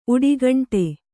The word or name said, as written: ಉಡಿಘಂಟೆ